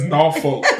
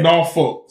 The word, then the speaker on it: Norfolk: It's Norfolk. That's how we say it. We country. We got country, little country twang, you know. And right. It's Norfolk. So even though it looked like it's spelled, it's really spelled N-A-W.